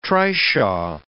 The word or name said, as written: trishaw